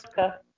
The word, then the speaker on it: Polska: Now, how do you say Pol- uh, Poland in, in Polish? I, am uh, curious. Polska. – Polska. Polska. Okay.